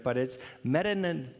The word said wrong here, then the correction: metanoia: metana yeah. Was that Brad? I, I, was it Brad? Did you yell it out? Someone yelled it out. Metanoia. Metananeo. Okay, there it is. It's on the screen.